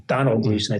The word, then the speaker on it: Domhnall: And I think if you watch, I mentioned The Patient, Steve Carell is great in that, as is Domhnall Gleeson, Donald Gleeson, I think it's pronounced, who's an Irish actor, whose father, Brendan Gleeson, was in Banshees of Inisherin, which won several Golden Globes.